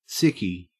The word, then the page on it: sickie: Australian English